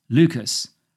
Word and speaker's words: Leuchars: Leuchars, pronounced like the name Lucas, which occurs later in the poem, used to be an RAF base from which fighter planes flew countless defence and reconnaissance sorties between 1920 and 2015.